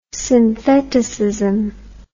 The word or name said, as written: syntheticism